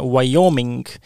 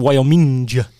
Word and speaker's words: Wyoming: aga järgmine teema viib meid Ameerika Ühendriikidesse ja osariik, mille õige hääldus, kusjuures ma olen ära unustanud tänu ühele kuulsale Youtuberile, kes meelega seda valesti hääldas, äh, Wyoming. Äh, aga iga kord, kui ma seda loen, mu pea tahab öelda Wyoming. – see on eestipärane lihtsalt äh, lugeda selliseid nimesid kuna noh, eesti keeles rõhk on ees, paneme siis niisugusele võõrsõnale kohe rõhu taha ja arvata, et on õigesti, aga no proovime Wyoming.